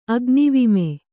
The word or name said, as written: ಅಗ್ನಿವಿಮೆ